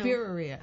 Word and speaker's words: birria: Birria. – Okay. – Yeah. – Okay. – Yeah. So I can't pronounce anything. – Yeah, it's a pizzeria only with a B. – I know. – Birria.